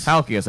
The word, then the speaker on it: Halkias: Halkias.